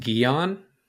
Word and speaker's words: Guion: It's pronounced Guion. – Like most people would not guess that. G-U-I-O-N.